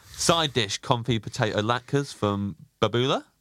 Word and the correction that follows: Bubala: Bubala. – Bubala.